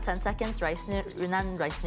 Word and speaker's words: Yunnan: I'm not saying that right, Yunnan, right? – Yunnan.